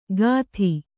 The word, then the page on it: ಕದಿ: kadi